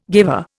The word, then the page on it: guiver: Indian